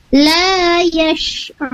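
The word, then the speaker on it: la yash'urun (لا يشعرون): Shin ya, pakai shin bukan sin. Jadi awas, hati-hati ketika huruf sin dan shin tertukar. Tadi Ibu Latifah membacanya dengan menggunakan huruf sin, la yash'urun. Untuk lebih benarnya, lebih tepatnya itu menggunakan huruf shin, posisinya, makhrajnya atau tempat keluarnya huruf shin sendiri agar sempurna bacaannya.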